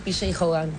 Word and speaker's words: P.J. Hogan: La segunda película que seleccionó acá la señorita es El Casamiento de Muriel's Wedding, una película australiana dirigida por P.J. Hogan, ¿se pronunciará? – Sí, P.J. Hogan.